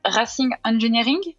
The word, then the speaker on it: Racing Engineering: C'était Racing Engineering.